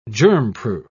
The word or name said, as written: germproof